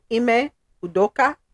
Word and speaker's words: Udoka: Celtics coach, this is right pronunciation, Ime Udoka, facing season-long suspension following improper intimate relationship with a female staffer.